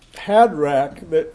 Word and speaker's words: Hadrach: Hadrach, it's a two-syllable word.